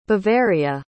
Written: bavaria

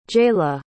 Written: jayla

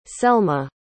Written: selma

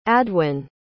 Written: adwin

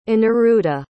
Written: anniruddha

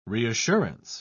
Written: reassurance

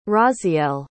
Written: Raziel

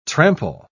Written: trample

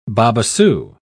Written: babassu

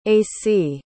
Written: acy

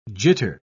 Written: jitter

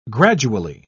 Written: gradually

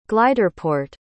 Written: gliderport

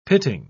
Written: pitting

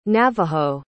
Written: navajo

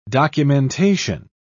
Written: documentation

